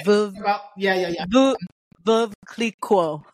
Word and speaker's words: Veuve Clicquot: But they told me that day they did an activation with Veuve Le Clicquot. I always say it wrong, but it's that really high-end champagne. – Yeah, okay. – Veuve Clicquot. – Yes, I know who you're talking about. – The Clico, yeah.